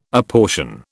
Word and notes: apportion: British English

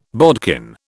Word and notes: bodkin: British English

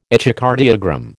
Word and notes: echocardiogram: American English

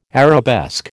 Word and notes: arabesque: American English

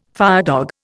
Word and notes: firedog: Indian

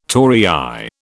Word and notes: torii: British English

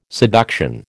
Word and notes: seduction: American English

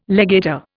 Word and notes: legator: Indian

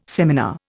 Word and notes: seminar: Indian

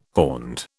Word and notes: horned: British English

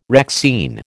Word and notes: Rexine: American English

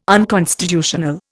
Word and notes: unconstitutional: Indian

